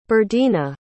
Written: birdena

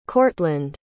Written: cortland

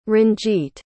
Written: ranjeet

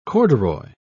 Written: corduroy

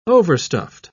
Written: overstuffed

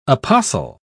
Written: apostle

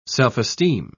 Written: self-esteem

